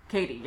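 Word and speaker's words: Cady: Katie, yes, I'm sorry. Caddy is the way to not pronounce her name from the film.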